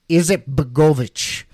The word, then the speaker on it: Izetbegović: The next one here, the next interesting quote, it is Elisha Izetbegović, I believe is how you pronounce that.